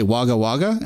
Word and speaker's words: Wagga Wagga: It's fun to say Wagga Wagga. Am I saying it right? – That's right.